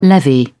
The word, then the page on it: levy: British English